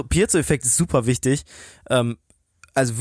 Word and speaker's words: Piezo-Effekt: Genau, Pierre ist genauer gesagt in der industriellen Physik ziemlich weit vorne mit dabei und er entdeckt den Piezo-Effekt.